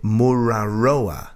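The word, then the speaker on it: Mururoa: Here in Walthamstow, we pronounce it Muraroa.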